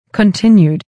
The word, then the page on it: continued: kəntɪ́njud; コンティニュード